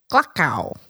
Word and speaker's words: Gluckow: Jen Gluckow. Gluckow. – People would always get it wrong, and I'd be like, it rhymes with luck, but it's Gluck.